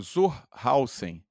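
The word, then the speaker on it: zur Hausen: O que, que Tsohauser fez? Me diz esse nome, me faz pronunciar certo esse nome, que eu vou pegar o nome. Então, aqui. o Zurhausen é o segunda personalidade fantástica e fundamental da nossa aula de hoje.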